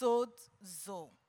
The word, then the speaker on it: sozo: Sozo, pronounced sodzo, S-O-D-E-zo, which means to save, to keep, to preserve, to deliver, and to heal.